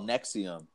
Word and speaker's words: NXIVM: The group's called Nexium, and it's pronounced, or it's spelled NXIVM.